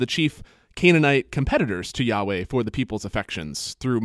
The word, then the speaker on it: Yahweh: the letters YHWH. Usually that's pronounced Yahweh,